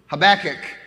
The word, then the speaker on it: Habakkuk: Mm, okay, Habakkuk, the traditional Hebrew pronunciation is the way we're pronouncing it. It's a little bit more difficult for me, so I got to think about it every single time, enunciating the right syllable.